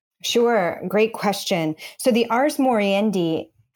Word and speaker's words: ars moriendi: So you talk in the very early on in the book, and I may be saying this wrong. I guess it's Latin. Say it for me. Ars moriendi. – That's perfect. Ars moriendi.